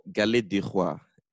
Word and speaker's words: Galette des Rois: Forgive me if I pronounce this wrong. I don't have the best French pronunciation, but Galette des Rois. That's it, mm-hmm.